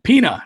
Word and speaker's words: Pina: I always say his name wrong, but Pina, it's Portuguese, not Spanish, boys.